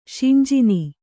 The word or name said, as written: ಶಿಂಜಿನಿ